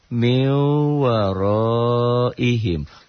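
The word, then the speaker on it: min warāihim: M-I-N, min, W-A-R-O-O. Min waro, gitu. Min waro, I, I, kasih I, him. Min waro ihim, ditulisnya. Dibunyikan, dibacanya, jadi N-nya double M. Jadi, mew, maaf, double M. Double W. Jadi, mew, mew waro ihim.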